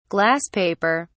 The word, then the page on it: glass paper: British English